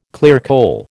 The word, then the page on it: clearcole: American English